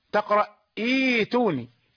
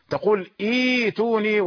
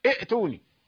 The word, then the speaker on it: ائتوني: وما بعد همز الوصل إيتي يعني في أول سورة الأحقاف إيتوني بكتاب، إذا بدأت بالكلمة تقرأ إيتوني، أنت أتيت بهمزة وصل ونطقت همزة الوصل، فلابد أن تبدل الهمزة الثانية للتسهيل، تقول إيتوني ولا تقول ائتوني، لا تقول ائتوني، ليس في اللغة العربية ائ ولا أ ولا أ، هذه الأصوات ليست موجودة.